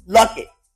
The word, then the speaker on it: Luckett: And I always say LaToya's name wrong. Is it Lockett? Yes. Luckett. L-U-C-K-E-T-T.